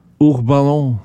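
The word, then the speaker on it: Hourbanon: H. Urbano. Du uttaler ikke H-en. – Nei. – H-O-U-R. – Urbano med stum H. – Stum H, ja.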